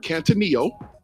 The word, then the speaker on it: katanoeo: When you talk about, when it talks about in the text, consider is the Greek word kantaneo, which is spelled K-A-T-A-N-O-E-O.